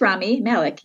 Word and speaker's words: Rami Malek: Isn't that how you pronounce his name? – Yeah, it's funny. I was going to ask you the same thing, but it's Rami. Yeah, I think it's Rami Malek.